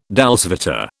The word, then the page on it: dolce vita: British English